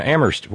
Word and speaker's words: Amherst: And thank you to Terry in Amherst, where only the H is silent.